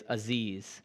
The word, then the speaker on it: Aziz: Aziz, I think you told me his name was, Aziz. Dina, who works here with me now, who handles this stuff, told me after I said it incorrectly on YouTube that it is Aziz.